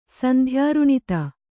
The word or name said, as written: ಸಂಧ್ಯಾರುಣಿತ